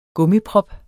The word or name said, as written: gummiprop